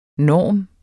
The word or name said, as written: NORMERNES